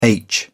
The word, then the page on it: aitch: British English